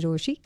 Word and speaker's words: Rochie: मेरा नाम छे मिर्ची रोशी, काम छे आपसे प्यार करना, नाम की स्पेलिंग RochiE.